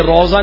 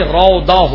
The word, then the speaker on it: روضہ: اس میں میں ایک تلفظ کی غلطی کی ہے, انہوں نے روضہ, یہ روضہ نہیں روضہ ہوتا ہے, یعنی ہم لوگ تو اردو میں زواد پڑھتے ہیں نا, ورنہ یہ عربی میں دواد ہے, تو زواد سے بھی لکھا جائے تب بھی یہاں انہوں نے زے سے لکھا ہے, یعنی روزہ.